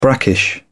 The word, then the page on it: brackish: British English